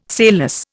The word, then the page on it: sailless: Indian